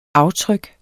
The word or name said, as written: aftryk